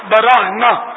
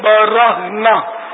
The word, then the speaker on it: برہنا: ہم نے یہ ضرور پڑھا ہے کہ وہ براہنا, یہ برہنا بولتے برہنا عام الفاظ میں, لیکن صحیح تلفظ ہے برہنا, برہنا برہنا یعنی کپڑے وغیرہ کچھ بھی نہیں ہوں گے بدن پر.